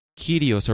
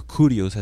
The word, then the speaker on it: Kyrios: Okay, the word Lord from the Greek Kyrios or Kurios as some pronounce it.